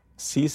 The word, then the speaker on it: Six: Cis se escreve S-I-X.